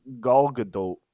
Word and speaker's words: Gal Gadot: It's Gal Gadot. That's actually how you pronounce her name, by the way.